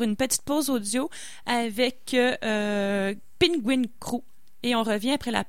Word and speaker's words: PNGWNG: Donc Simon Roy nous propose cette semaine un artiste dont j'avais beaucoup de difficultés à lire le nom du groupe, mais je pense que ça se prononce Penguin Crew.